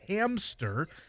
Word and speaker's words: hamster: I put the P on it, and I noticed that it's you're not supposed to. It's hamster.